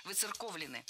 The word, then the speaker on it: выцерковлены: И здесь, я думаю, вы уже догадались, ударение должно сохраняться на третьем слоге – «выцерковлены».